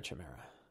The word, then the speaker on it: chimera: how do you pronounce it? Is it chimera or chimera? Chimera. I was right.